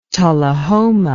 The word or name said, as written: Tullahoma